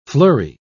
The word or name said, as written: fleury